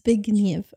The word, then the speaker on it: Zbigniew: How do you say it? It's Zbigniew.